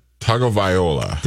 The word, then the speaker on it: Tagovailoa: Tua uh, Tua Tagovailoa.